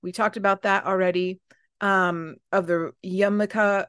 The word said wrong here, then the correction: Yamuna: or no, I'm saying that wrong. Yamunka River. Yamun. – You've Wait got this. It. Loading so hard. – Um, Yamuna River.